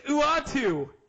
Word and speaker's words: Uatu: and he likes that good old useless colossal Utah, the Watcher, and they should do an updated short event. – Wait, hold on. How do you pronounce that? – Utah? – It's Uatu.